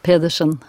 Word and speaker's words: Pedersen: Je crois qu'on dit Pedersen, c'est ça, hein Comment vous prononcez votre nom euh, Grete Pedersen. – Ah, ce sera bien mieux comme ça.